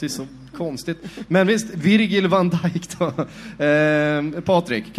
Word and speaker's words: Virgil: Uttala hans förnamn, är det, vi, det, var, det var Virgil? Rätt vanligt, det är ett Virgil.